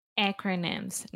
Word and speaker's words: acronyms: First, the pronunciation we say acronyms. So the first sound is that really open "a" sound that we have in English, just like apple, hat, cat, disaster, master. You get the point? – Yes, I think so.